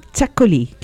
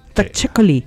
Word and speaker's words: Txakoli: Y uno de los tragos, digamos, licores íconos del País Vasco es el Txakoli, que se escribe con T-X-Coli. Txakoli, pero aquí en Chile es Txakoli, con Che de Chile.